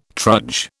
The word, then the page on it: trudge: British English